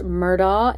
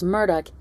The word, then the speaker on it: Murdaugh: I have heard their last name pronounced Murdaugh and Murdoch. I heard Buster pronounce it as Murdoch in court, so that's how I'm going to pronounce it.